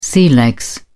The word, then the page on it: sea legs: British English